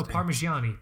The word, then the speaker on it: Parmigiani: No, Parmigiani, par par Parmigiani, chicken Parmigiani. I can't ever say this fucking brand's name for some reason. Um, what the That's is actually exactly how you say it.